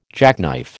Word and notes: jackknife: American English

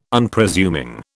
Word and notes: Unpresuming: British English

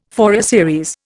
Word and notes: Fourier series: Indian

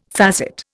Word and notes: facet: Indian

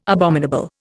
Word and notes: abominable: Indian

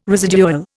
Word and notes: residual: Indian

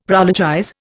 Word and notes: prologize: Indian

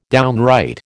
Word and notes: downright: American English

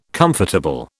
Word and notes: comfortable: British English

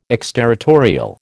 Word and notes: exterritorial: American English

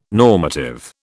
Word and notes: normative: British English

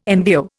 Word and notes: MVO: Indian

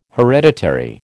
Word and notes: hereditary: American English

